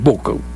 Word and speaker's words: Buch: Bueno, hablemos sobre Juan Pablo Buch, o Buch, lo correcto es Buch, pero se escribe con che final, Buch.